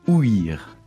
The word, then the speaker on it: ouïr: Et alors, il se prononce bien « ouïr ». On doit bien marquer l'accent sur le « i ». – Parce qu'il y a un tréma qui se trouve là.